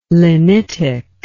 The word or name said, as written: lenitic